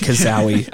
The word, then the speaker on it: cazaui: I'll spell the last name because I might pronounce it wrong. C-A-Z-A-U-I. – I'm just going to... Yeah, kazawi works.